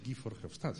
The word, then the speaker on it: Guy Verhofstadt: Pero como ha surgido el asunto de las dos velocidades, y además el profesor Caramés ha citado a Guy Verhofstadt, se pronuncia así, Guy Verhofstadt, sin más, no tiene mayor truco.